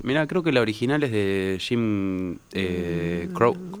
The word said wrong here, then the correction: Croce: No sé cómo se pronuncia. – Croce.